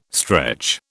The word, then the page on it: stretch: British English